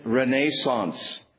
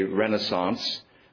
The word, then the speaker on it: Renaissance: And this became known as the Carolingian Renaissance. Most Americans say Renaissance, but Renaissance, the Carolingian Renaissance.